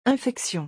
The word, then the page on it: infection: French